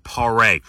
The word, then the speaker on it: Paré: There's a little line over the E, so you pronounce it Paré.